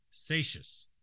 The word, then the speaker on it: Seixas: That's Vic Satius, is how you say his last name.